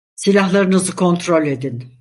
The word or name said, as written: kontrol